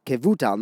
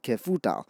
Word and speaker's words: Kevutal: but he said that Rav Zachariah ben Kefutal with a fe instead of a ve, with a vase. And Rav gestured with his hand and said, no, you mispronounced that. It's Kevutal, not Kefutal.